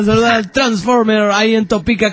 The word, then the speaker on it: Topeka: Dice, la palabra Topeka se pronuncia en castellano como Topeka.